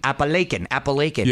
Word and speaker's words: Appalachian: And Appalachian, am I pronouncing? – Appalachian.